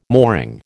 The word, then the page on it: mooring: American English